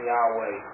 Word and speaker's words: Yahweh: Sometimes we call it Jehovah, but it would have been pronounced more like Yahweh.